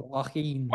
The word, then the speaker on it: Joaquin: The only thing I would point out about Jaco Neiman, how do you pronounce his first name? Jaco? – Joaquin.